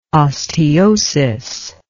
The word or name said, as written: osteosis